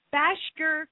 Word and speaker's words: Bashker: And we have on the phone from the American Bashker Curly Registry. Hey, how are you? – I'm fine, thank you. And you pronounce that very well. – Really? – Yes, Bashker is a difficult word.